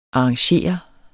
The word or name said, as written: arrangere